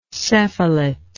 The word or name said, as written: cephalate